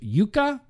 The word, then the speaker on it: Jukka: I believe Jukka would be pronounced Jukka from Finland. Jukka.